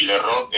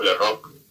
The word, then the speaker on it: LeRocq: Eh, y si lo, hay, lo que sí hay que escribirlo, eh, Paul, como se escribe el nombre en inglés, y la Rock es L-E-R-O-C-Q, la Rock con q al final,